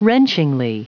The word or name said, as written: wrenchingly